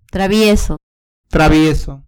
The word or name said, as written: travieso